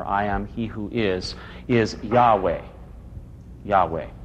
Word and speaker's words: Yahweh: but their English equivalents would look like a J-H-W-H, without any vowel, with some markings there to indicate where the vowel went. So those who know the Hebrew language best say that the closest pronunciation was Yahweh.